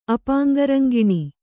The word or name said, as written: ಅಪಾಂಗರಂಗಿಣಿ